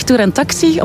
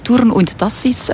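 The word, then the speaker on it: Thurn und Taxis: De naam, daar bestaat wel discussie over. Ik zelf zeg Tour en Taxi, omdat ik het gemakkelijker uitspreken vind. Maar eigenlijk in het Nederlands zou het van Tour und Taxis moeten zijn. Fransen zeggen dan eerder Tour en Taxi.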